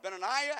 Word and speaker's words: Benaiah: Benaiah, or Benaiah, as some pronounce it.